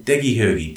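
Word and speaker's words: Degi Heugi: It sounds not easy if you say it that way. Degi Hoogie, D-E-G-I, Degi, and Hoogie, H-E-U-G-I.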